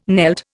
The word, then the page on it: knelt: Indian